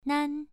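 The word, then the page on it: nan: tone 1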